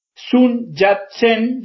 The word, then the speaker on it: Sun Yat-sen: Actualmente es investigador y profesor de la Universidad Sun Yat-sen, ya me voy a decir si lo pronuncié bien, en la República Popular China, donde coordina el proyecto China Lab 21. Hola Mariano, muy buenas noches, muchas gracias por acompañarnos. – Bueno, bien pronunciado, así que perfecto todo.